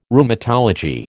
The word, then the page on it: Rheumatology: American English